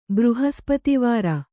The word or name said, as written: ಬೃಹಸ್ಪತಿವಾರ